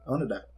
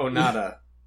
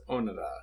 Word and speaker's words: Onoda: Not Onada. It's Onoda. – Onoda. Because, like, there's no stressed syllables. – Okay. – Or all the syllables are stressed. They don't do the stressed, unstressed thing.